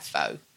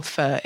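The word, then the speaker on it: phở: Like, you know, in Vietnam, every morning I had pho, which is bonkers, or pho, if you're going to say it properly, but which is bonkers for us.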